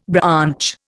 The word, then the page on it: branch: Indian